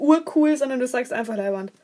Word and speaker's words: leiwand: Äh, wahrscheinlich werde ich es jetzt falsch aussprechen. Lavand, richtig oder falsch? – Leiwand, meinst? – Ja. – Das ist Leiwand.